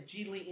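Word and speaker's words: Gigli: He says it as Geely in the movie, so I'm going to go with the way the character pronounces it, because it's the character's name.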